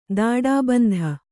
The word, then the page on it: ದಾಡಾಬಂಧ: dāḍābandha